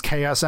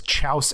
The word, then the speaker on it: Chaos: I used to pronounce Chaos Emeralds as Chouse Emeralds because I didn't know what the word was.